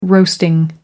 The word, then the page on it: roasting: American English